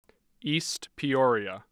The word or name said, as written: East Peoria